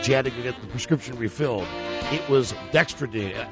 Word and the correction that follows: Dexedrine: Am I pronouncing that correctly, Buzz? – Dexedrine? – Yeah. – Dexedrine.